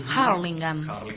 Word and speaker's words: Hurlingham: Ellos están en zona oeste, viven en Hurlingham. Harlingham. Bien pronunciado.